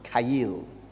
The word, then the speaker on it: chayil: Kail is a Hebrew word spelled C-H-A-Y-I-L. Actually, you'd say Chayil, but in the Hebrew, it's pronounced Kail.